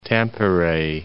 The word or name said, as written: Tampere